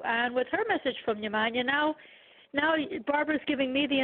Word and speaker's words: Yemanja: It's pronounced Yemanja. – No. Yemanja. – Okay, Yemanja or Yemanja. Either or is a proper pronunciation.